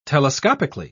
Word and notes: telescopically: British English

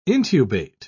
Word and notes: intubate: British English

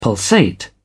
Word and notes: pulsate: British English

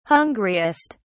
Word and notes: hungriest: British English

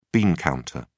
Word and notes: bean counter: British English

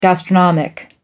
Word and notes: gastronomic: American English

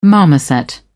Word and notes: marmoset: British English

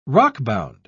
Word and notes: rockbound: British English

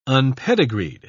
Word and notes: unpedigreed: British English

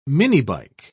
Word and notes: minibike: British English

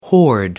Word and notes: horde: American English

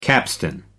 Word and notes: capstan: American English

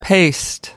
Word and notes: paste: American English

